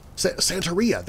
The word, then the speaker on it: Santería: Santería, is that right? Santería? Am I even saying that right? Santería? Santería, that's the way it's said.